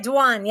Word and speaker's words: Dwan: That's right, Dwan.